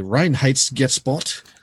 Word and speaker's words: Reinheitsgebot: And in German, that's pronounced apparently Reinheitsgebot.